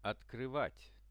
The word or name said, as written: otkryvat